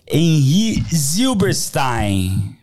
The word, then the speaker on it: Henri Zylberstajn: Henri Zilberstein. Falei certo? Super certo.